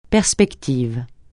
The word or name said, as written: perspective